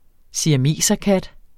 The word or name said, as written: siameserkat